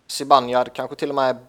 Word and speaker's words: Zibanejad: Ja, eh, alltså, om vi ska vara helt korrekta så uttalas det Zibanejad. – Okej, okay, ja, bra. Då har jag lärt mig det också. – Zibanejad. In, inget E.